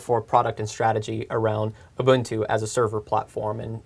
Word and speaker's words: Ubuntu: And by the way, there's a correct way to pronounce it. You all listen up. – Ubuntu. – Ubuntu. Yes, I was coached.